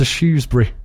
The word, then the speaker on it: Shrewsbury: It is pronounced Shrewsbury, apparently, not Shrewsbury.